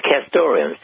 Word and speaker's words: castoreum: I believe the pronunciation is castoreum. Is that correct? – Well, you just taught me something. It is castoreum, C-A-S-T-O-R-E-U-M.